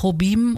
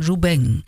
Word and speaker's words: Jobim: Also nicht Jobim und auch nicht Jobim, sondern Ju Beng. Also, Antonio Carlos Jobim. So wäre die richtige Aussprache.